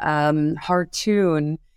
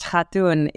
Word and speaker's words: Khartoon: Hartoon is actually pronounced Hatun. It is a Turkish name.